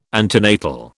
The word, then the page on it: antenatal: British English